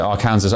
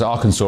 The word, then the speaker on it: Arkansas: Louisiana, Alabama, and not as I pronounced it, Arkansas, Arkansas, of course.